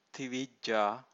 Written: tivijja

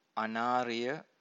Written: anariya

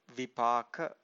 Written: vipaka

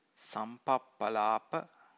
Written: sampappalapa

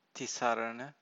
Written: tisarana

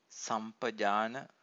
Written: sampajana

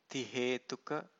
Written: tihethuka

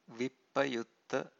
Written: vippayutta

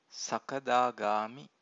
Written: sakadagami

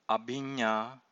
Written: abhinnā

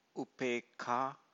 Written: upekkha

